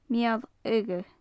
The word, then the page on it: mial-fhiodha: gaelic